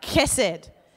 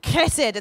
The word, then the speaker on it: chesed: which is chesed. I'm not saying it right. Chesed. Chesed. It's got that K in there.